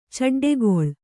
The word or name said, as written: ಚಡ್ಡೆಗೊಳ್